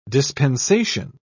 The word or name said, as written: dispensation